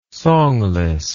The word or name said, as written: songless